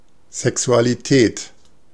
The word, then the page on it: Sexualität: German